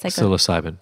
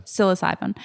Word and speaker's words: psilocybin: Psilocybin. – Psilocybin.